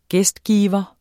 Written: gæstgiver

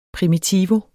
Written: primitivo

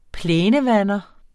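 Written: PLÆNEVANDERE

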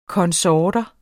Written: konsorter

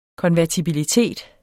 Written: konvertibilitet

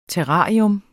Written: TERRARIER

